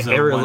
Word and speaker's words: ailerons: So the systems that sort of take care of, the engines run and how the aerolons or whatever you call them. So yeah. – Aerolons. How do you say it? – Ailerons. One term I know.